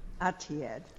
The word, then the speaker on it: Etihad: Etihad. – Yes. – Okay. Thank you. – So for purposes of the alter ego issue that you're raising, Judge, there were multiple counts that were against Etihad, including negligent retention.